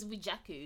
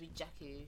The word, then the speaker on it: Rejiaku: It's at R-E-J-I-A-K-U. – How do you say that? – It's Rejaku, which is my first. Rejaku. Yeah, my first and last name joined together. – Oh, okay, I see. – Yeah, so it's just Rejaku.